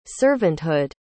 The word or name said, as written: servanthood